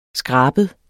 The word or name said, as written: skrabet